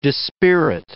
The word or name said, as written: dispirit